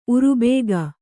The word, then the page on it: ಉರುಬೇಗ: urubēga